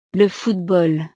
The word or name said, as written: le football